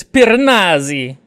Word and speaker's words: Piranesi: And I just really, really, really like how genuine and innocent Piranesi, whatever. – It's Piranesi. – No. – That's how it's pronounced. Piranesi.